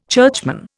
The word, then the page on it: churchman: Indian